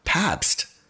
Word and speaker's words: Pabst: William's daughter, Hilda, married Gustav Pabst. Am I saying it right? Yes.